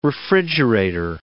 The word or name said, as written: refrigerator